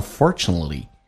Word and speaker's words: unfortunately: 来看一下 unfortunately, 最后一个 T 是不用发音的，在美式发音当中 T 跟 L 组合 T 不发音，叫 unfortunately,